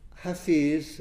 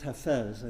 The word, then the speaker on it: Hafiz: Hafiz, sometimes pronounced Hafez, I think. I had a native Farsi speaker corrected me once. H-I-F-I-Z, sometimes spelled H-A-F-E-Z, but I've usually seen it H-A-F-I-Z.